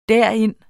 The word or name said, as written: derind